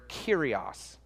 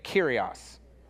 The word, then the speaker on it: Kyrios: Now, in the Greek that this hymn and this letter was written in, the word behind Lord is the word Kyrios. Okay, say it with me, Kyrios. Kyrios, great, you got it. If you're trying to spell it, it's like curious, but with a K and no U. I don't know if that helped, but just sound it out.